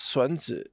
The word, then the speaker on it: Sun Tzu: In Chinese, it's pronounced Sun Tzu.